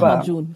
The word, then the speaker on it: Lahmacun: Lahmacun, ich hoffe, ich habe es richtig ausgesprochen. – Genau, genau.